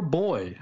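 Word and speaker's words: boi: At a burlesque club, an altar boy... It's B-O-I. I don't know how to pronounce that. – It's boy.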